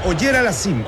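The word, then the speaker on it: Auger-Aliassime: Puede jugar contra Autoutem, que es el local, pues es, disculpe la pronunciación, Auger Aliassime. – Es Auger Aliassime, el canadiense. Félix Auger Aliassime.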